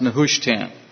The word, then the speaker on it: Nehushtan: If you are a Hebrew scholar, you may pronounce it differently, but that's how I see it being pronounced, Nehushtan.